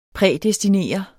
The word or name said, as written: prædestinere